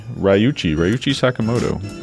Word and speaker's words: Ryuichi Sakamoto: Ryu Sakamochi's band, if I'm pronouncing that correctly. Probably not. Sorry. Ryuichi Sakamoto. My God. Must be the end of the day on a Monday. Ryuichi Sakamoto. That's what I was going for with Yellow Magic Orchestra.